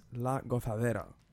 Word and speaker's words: La Gozadera: Could you help me with that, the pronunciation? – Yeah, it's called the... Gente de la Zona featuring Mark Anthony, and it's La Gozadera.